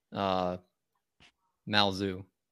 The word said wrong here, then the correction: Lao Tzu: So, mispronounced his name, but it is Lao Tzu.